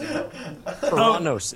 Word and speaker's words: Uranus: Uranus is the proper pronunciation.